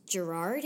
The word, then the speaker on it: Gerard: I think it's Gerard. It's spelled with a G.